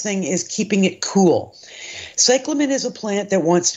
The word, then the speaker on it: cyclamen: It's C-Y-C-L-A-M-E-N. – Mm-hmm. And it is a lovely plant. It's the way you pronounce that is cyclamen.